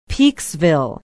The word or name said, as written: peeksville